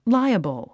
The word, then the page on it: liable: láiəbl; ライアボォ